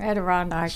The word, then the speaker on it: Adirondack: I seem to remember she could not remember how to say Adirondack. – Adirondack. That's right.